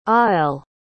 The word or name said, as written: aelle